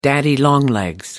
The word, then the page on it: daddy longlegs: American English